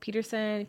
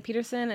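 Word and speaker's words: Petersen: Yeah, you can follow me at Tori Hope Peterson. Peterson is S-E-N, not S-O-N.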